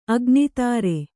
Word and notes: ಆಗ್ನೀಧ್ರ: āgnīdhra

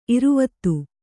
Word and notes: ಇರುವತ್ತು: kannada

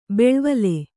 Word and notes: ಬೆಳ್ವಲೆ: beḷvale

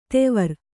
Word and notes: ದೇವರ: Kannada

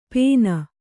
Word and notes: ಪೇನ್: pēn

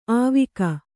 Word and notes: ಆವಿಕ: āvika

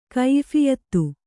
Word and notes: ಕೈಯಫಿಯತ್ತು: kaiyaphiyattu

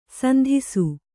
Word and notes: ಸಂತಸ: santasa

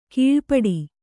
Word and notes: ಕೀಳ್ಪಡು: kīḷpaḍu

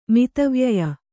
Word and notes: ಮದುವೆಯಾಯ: Kannada

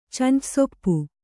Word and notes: ಚಂಚಸೊಪ್ಪು: kannada

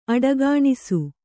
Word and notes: ಅಡಗಾಣಿಸು: aḍagāṇisu

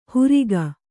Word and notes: ಹುರಿಗ: huriga

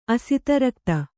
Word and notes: ಅಸಿತರಕ್ತ: asitarakta

